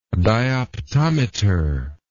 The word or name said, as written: dioptometry